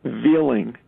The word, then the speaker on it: Veiling: His last name is spelled V-E-I-L-I-N-G. ENG, Veeling, and they've never pronounced it right.